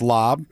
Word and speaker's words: larb: Other dishes I tried were the chicken lob, which is written L-A-R-B, but it's pronounced lob.